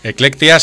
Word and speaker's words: Eclectia: Lo del título, Electia, que yo no sé si lo digo bien o lo digo mal, o lo habéis inventado, lo habéis buscado, es latino. – Eclectia, sí, Eclectia viene del latín,